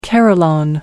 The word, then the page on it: carillon: American English